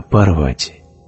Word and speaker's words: Парвати: И я тут же узнала, что это Поверти. Поверти – это спутница Шивы». – Не Поверти, а Парвати.